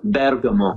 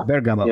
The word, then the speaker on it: Bergamo: Bergamo. – Bergamo.